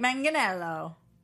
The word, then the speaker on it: Manganiello: No, people keep yelling at us, hard G, like Manganiello.